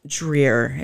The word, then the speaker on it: Dreer: It's Dreer. It rhymes with beer.